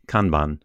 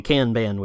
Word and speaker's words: Kanban: Well, on pronunciation, I wouldn't say I'm an expert, but I always heard it as Kanban. – Always say Kanban, which is a very American pronunciation. I think it's the Japanese is probably different from both of us.